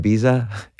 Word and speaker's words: Ibiza: One of your songs, I Took a Pill in Ibiza. Is that how you say it? Ibiza? – Yeah, that's how I say it.